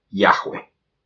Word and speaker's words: Yahweh: Y al final se concluyó de que se pronunciaba Yahweh exactamente como lo pronuncian ahora los judíos.